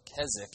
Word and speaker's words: Keswick: A few decades later, in a region of England called Keswick, or Keswick, you don't actually pronounce the W, a correction to the Wesleyan theology was made.